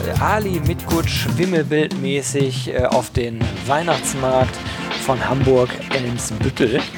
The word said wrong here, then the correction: Eimsbüttel: Hoffe ich zumindest, dass ich das jetzt richtig gesagt habe. – Eimsbüttel. – Eimsbüttel. Ich werde schon korrigiert hier von Jo Dirks von SideQuest und vom Recruitment-Blog.